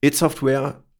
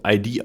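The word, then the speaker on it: id Software: It Software ich habe es jetzt hier, ich musste mich echt zusammenreißen, dass ich es dauernd richtig ausspreche. Es wird fälschlicherweise oft ID ausgesprochen, also ID-Software. Das habe ich auch jahrelang gesagt, aber ich habe mir mittlerweile, glaube ich, weitestgehend It-Software angewöhnt.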